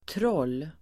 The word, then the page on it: troll: [trål:]